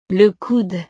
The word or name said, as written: le coude